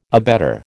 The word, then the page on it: abetter: American English